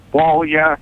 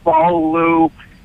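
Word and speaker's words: Beaulieu: Yeah, well, it's pronounced a few different ways. Some people pronounce it Blue and Taunt, and they pronounce it Bolya, Bolu. It's the same name. It's the same thing as Elvis Presley's wife. That's a famous Bollier. – Bollier. – She pronounces it Ballou.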